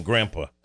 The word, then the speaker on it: grandpa: grandpa. – Yeah, you shouldn't say grandpa. Get more out of that last syllable.